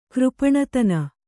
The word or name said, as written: ಕೃಪಣತನ